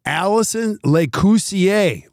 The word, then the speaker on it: Allison Lacoursiere: Allison Lacoursiere.